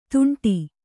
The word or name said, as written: ತುಂಟಿ